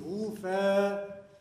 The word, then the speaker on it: Rufe: Never seen Rufe. It's very dull, quiet syllable. Rufe.